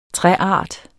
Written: træart

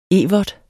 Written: evert

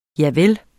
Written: javel

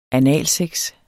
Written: analsex

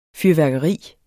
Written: fyrværkeri

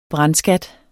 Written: brandskat

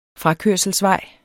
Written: frakørselsvej

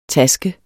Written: taske

